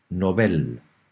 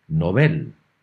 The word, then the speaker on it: Nobel: Pues Andreas, empezando por el principio, tienes que saber que estos premios llevan el apellido de Alfred Nobel, que según el diccionario panhispánico de dudas, se debe pronunciar como en sueco, es decir, Nobel, y no Nobel, como se acostumbra a pronunciar en castellano.